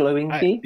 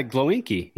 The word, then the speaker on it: Glowinski: Do you say it like that? Glowinky? – Yeah, Glowinky. Yeah, it rolls off my tongue.